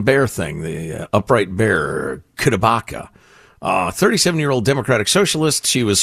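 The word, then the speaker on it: CdeBaca: It's spelled C, small D-E, capital B-A-C-A. Kudabaka.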